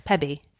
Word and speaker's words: Pebe: PEBE. I think that's how you say it. Pebby. PB. PB. – Oh, PB. Oh, right. As in, like, not the initials.